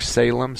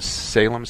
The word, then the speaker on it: Salem: Mark with a K at Mark Salem. Salem spelled like the cigarette.